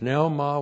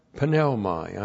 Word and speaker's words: pneuma: E-I-M-I, the Greek to be verb, means it is or is. And then we have pneumai. I know you pronounce it pneuma, but that's no self-respecting Greek would ever say pneuma. They pronounce every, there are no silent letters when they spoke.